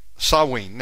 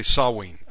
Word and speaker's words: Samhain: And basically, the article says, beware of the bogs of Samhain. Now, you know, Samhain. – Samhain, yeah. – That's the way I've always pronounced it. Someone corrected me and said it's actually Samhain.